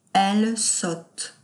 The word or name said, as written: elle sotte